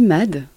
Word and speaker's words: MAD: Alors on dit MAD, mais ça vient, c'est l'anagramme de Mutual Assured Destruction.